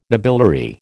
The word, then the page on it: nobiliary: American English